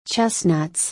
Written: chestnuts

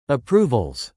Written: approvals

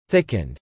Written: thickened

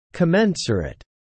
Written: commensurate

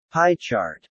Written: pie chart

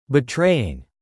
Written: betraying

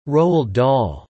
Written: Roald Dahl

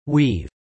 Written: weave

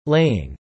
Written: laying

